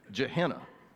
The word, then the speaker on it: Gehenna: but Gehenna. Did I get it right? Don says yes, I got it right. Okay, Gehenna.